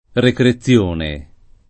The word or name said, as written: recrezione